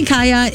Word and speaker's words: Kaya: Now, apparently Kaya is a common girl's name in Scandinavia, and when written with a Y, like K-A-Y-A, it's pronounced the same in both English and Norwegian.